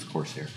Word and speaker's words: Corsair: Corsar. How do you say his name? Corsar. Corsar. – I believe it's Corsair. – Corsair? Yeah, it's got an I in it, right? – Yeah.